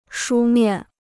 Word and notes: 书面: shū miàn

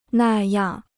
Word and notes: 那样: nà yàng

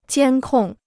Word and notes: 监控: jiān kòng

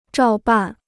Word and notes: 照办: zhào bàn

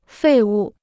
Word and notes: 废物: fèi wù

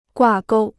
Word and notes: 挂钩: guà gōu

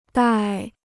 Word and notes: 戴: dài

